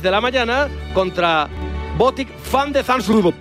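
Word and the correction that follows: Botic van de Zandschulp: ¿Lo he dicho bien? Bueno, parecido. En neerlandés, Botic van de Zamsloop.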